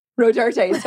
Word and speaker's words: Rodarte: Rodart or Dior? – Rodarte. – Rodarte, sorry. – Rodarte. – No, Rodarte. I always said it wrong.